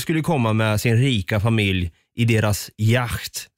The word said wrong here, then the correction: yacht: säger man yacht? Du som är bra på tyska uttal. Yacht. Yacht, säger man då ja.